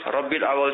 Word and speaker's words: ربیع الاول: یا سیدی یہ ارشاد فرمائیے، ہمیں ربیع الاول شریف کی کیسے تعظیم بجا لانی چاہیے؟